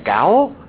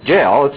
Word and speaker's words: gaol: Gallant, galleries, galleys, galling, jail, pronounced spelled G-A-O-L. And first time I saw that, I thought, gowl? I had no idea how to pronounce that. But it's pronounced jail, it's just spelled different.